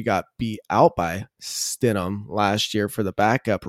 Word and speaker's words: Stidham: I don't even know how to say his name. I'm not too familiar Stidham. – With the guy. Stidham. – Silent H. – Yes. – Stidham.